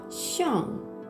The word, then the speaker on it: 上: でですね、その次、その次の文字がシャン、シャン。